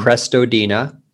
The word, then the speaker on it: Crestodina: My name, as you know, is spelled like it sounds, mm-hmm. Crestodina.